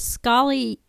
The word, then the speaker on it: Scholly: And then the third was Scholly, and that's an app.